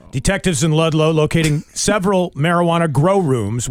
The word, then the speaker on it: Ludlow: Two men charged in a marijuana grow operation in Ludlow. right? Isn't that how it's pronounced? – No, it's Ludlow.